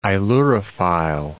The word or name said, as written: ailurophile